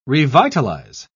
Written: revitalize

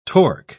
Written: torc

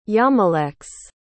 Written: yamilex

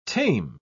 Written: tame

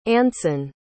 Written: anson

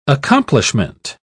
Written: accomplishment

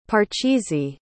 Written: parcheesi

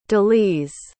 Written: deliz

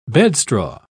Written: bedstraw

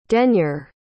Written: denyer

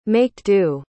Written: make-do

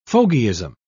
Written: fogyism

